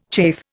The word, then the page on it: chafe: Indian